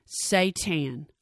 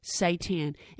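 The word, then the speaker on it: seitan: So I looked at the pronunciation of this word, and it is pronounced, hear me now, Satan. What did you say? That's right, Satan.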